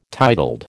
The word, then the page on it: titled: American English